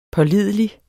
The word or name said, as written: pålidelig